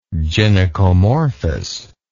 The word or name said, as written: gynecomorphous